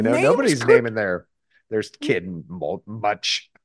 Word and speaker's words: Much: His name is Much. – Okay, great. Cool. – And in this text, it appears M-O-C-H-E. But it's actually in modern times referred to as M-U-C-H. And that's how it's pronounced.